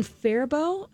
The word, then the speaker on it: Faribault: And that's Highway 60 in Faribault.